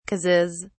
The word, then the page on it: causes: British English